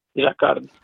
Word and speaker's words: Jacquard: His artwork series come with overarching titles like Equations, Jacquard Routing. Did I pronounce that correctly? – Yes. Jacquard.